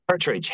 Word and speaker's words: Herterich: Herterich, Herterich.